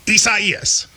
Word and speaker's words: Isaias: A lot of people, you know, prayers to everybody involved, but it is Isaias.